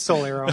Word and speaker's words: Soul Arrow: No, it's Soul Arrow. You just said it. Just when you say it, it sounds like you're saying Solero.